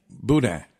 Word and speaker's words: Boudin: Boudin. – Buddha. I wasn't even close. – Boudin. – Okay. Do you know how to spell it? – B-O-U.